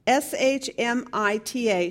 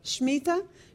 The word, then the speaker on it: Shmita: The seventh year sabbatical called S H M I T A, Shmita. Does anybody know how to pronounce that? Shmita? Shmita, okay, in Hebrew.